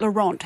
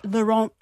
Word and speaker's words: Laurent: I don't think you pronounce the T on the end of Laurent.